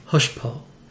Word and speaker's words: hochepot: And hotchpot dates clear back to the 14th century and is taken from the French word hushpo, which is a kind of stew. – And how we can spell that. – So that's hochepot. – Okay. – Hushpo. And we can trace its etymology a bit further. The hoche in hushpo is from the French hoche, which means to shake. And po, which, though pronounced differently, means and is spelled exactly like the English word pot.